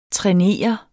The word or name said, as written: træner